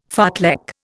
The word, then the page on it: fartlek: Indian